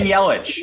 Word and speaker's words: Yelich: It rhymes with the first part of the pronunciation of his name rhymes with smell. – Of his last name? – Yeah. – Christian Yelich.